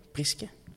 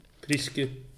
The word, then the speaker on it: Priske: Dat is de consensus, zo gaan we het uitspreken. Priske? Priske. Priske, voilà. Hans, een verrassing voor jou?